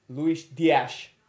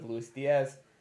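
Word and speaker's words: Díaz: Sadio Mane in the 64th, Mo Salah in the 67th, and Luis Dias, as I have a lot more Portuguese people on my TikTok than I ever realized, and that's, I guess, how you pronounce his name, instead of Luis Diaz, in the 81st.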